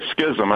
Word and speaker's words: Schism: But back up even further to the year 1054, where you have what's called the Great Schism, or the Great Schism. I think it can be pronounced either way.